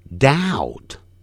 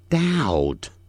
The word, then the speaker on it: doubt: 我们再把下面的字练出来，跟着这样念一下，像母牛或乳牛，cow cow cow 变成 cow 啊 cow，大声的 loud loud，怀疑 doubt，先念大小的大 doubt，猫头鹰 owl。